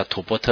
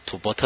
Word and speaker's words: 吐蕃: 所以我们可以看到明代甚至元代的时候，有时候称这个地方叫吐蕃特，好，啊，吐蕃特。那我们看到唐代我们读书的时候叫吐蕃。啊，那个字是写吐蕃啦，啊，但是发音是吐蕃，其实就是我们今天发音 Tibet 哈，啊，这个变成英文发音就变 Tibet 哈，啊。